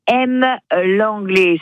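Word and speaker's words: Hem-Lenglet: Demain, à M l'anglais, ça s'écrit H-E-M, trait d'union, L-E-N-G-L-E-T.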